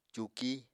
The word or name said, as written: cuuki